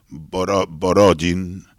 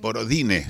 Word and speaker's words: Borodin: Por ejemplo, el músico ruso Borodin, se escribe Borodine. Borodine se escribe. Nosotros escribimos Borodin, pero los franceses lo escriben Borodine para no decir Borodin.